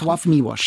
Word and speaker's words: Czesław Miłosz: Ein Buch von Czesław Miłosz. Habe ich das richtig ausgesprochen? – Czesław Miłosz, genau. Czesław Miłosz. Die L's haben beide so Striche durch und die werden so Auf meinem Buch nicht, interessanterweise.